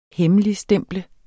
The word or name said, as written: hemmeligstemple